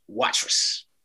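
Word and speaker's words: Watrous: So, you know, we talked a little bit about, so you were born in, is it Watrus, Saskatchewan? Am I saying that right? – Watrus. – Watrus, okay.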